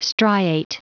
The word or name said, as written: striate